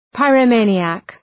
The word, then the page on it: pyromaniac: ,paırə’meınıək